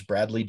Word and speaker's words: Bradlee: Easily at, my name is Bradley W. Skinner. So that's Bradlee, named after my father, Lee. So they spell it with the two E's.